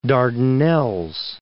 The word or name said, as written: Dardanelles